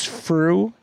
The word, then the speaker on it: Frueh: By the way, when you introduce me, remember my name is Frueh. It rhymes with true. It rhymes with blue.